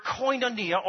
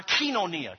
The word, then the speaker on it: koinonia: The plan there is the Greek word koinonia or kinonia. Kinonia is how they say it in Greek.